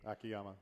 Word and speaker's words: Akiyama: Akiyama.